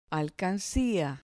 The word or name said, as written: alcancia